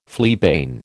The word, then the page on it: fleabane: American English